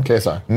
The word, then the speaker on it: KSI: KSI.